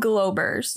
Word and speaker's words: Globeers: Oh, so there is actually two E's. – Yeah. – And it's called Globers. – Yeah. – But it's spelled Globeers.